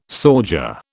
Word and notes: sauger: British English

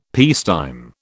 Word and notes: peacetime: British English

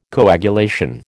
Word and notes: coagulation: American English

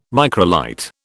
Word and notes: microlight: British English

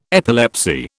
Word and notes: epilepsy: British English

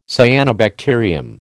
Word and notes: cyanobacterium: American English

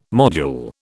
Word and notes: module: British English

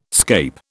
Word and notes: scape: British English

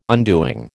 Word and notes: undoing: American English

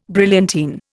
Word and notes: brilliantine: Indian

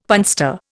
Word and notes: punster: Indian

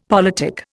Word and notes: politic: Indian

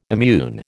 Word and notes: immune: American English

